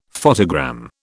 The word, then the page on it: photogram: British English